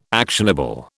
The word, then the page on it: actionable: British English